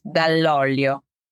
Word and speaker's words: Dall'Olio: And my surname is Dall'Olio.